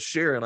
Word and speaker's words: Sharon: You know, it's really simple. It's Sharon, like the lady's first name.